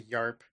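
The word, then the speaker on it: Jarp: It's actually yarp. yeah. It's a silent G, hard J. Yarp.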